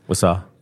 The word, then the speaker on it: Wausau: Up in Wausau, Wisconsin. I think I'm saying that right. – Wausau? – Yeah.